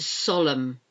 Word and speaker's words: solemn: And solemn. S-O-L-E-M-N. You don't pronounce the N. It's silent.